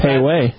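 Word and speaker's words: Pei Wei: A Pee-wee. – Pee-wee? – Maybe it's Pie-Y. I'm pronouncing it wrong. – Yeah, well, the E comes before I. – Yeah, P-I-Y. – It should be Pei-Wei.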